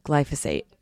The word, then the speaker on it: glyphosate: They found ridiculous amounts of, what is it, glyphosate? Let's go back, hold on. Glyphosate, that's how you pronounce it.